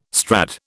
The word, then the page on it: Strad: British English